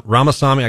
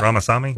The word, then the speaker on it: Ramaswamy: whether it's DeSantis or Ramaswamy. Am I saying that right? – Yep. Vivek Ramaswamy? – Yeah.